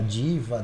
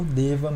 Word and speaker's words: Deva: Nós temos clientes que falam Deva, Diva, Deua, mas a gente pronuncia aqui como Deva mesmo, né? Como se fosse um V.